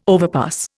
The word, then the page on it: overpass: Indian